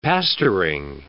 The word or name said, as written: pastoring